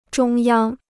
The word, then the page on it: 中央: zhōng yāng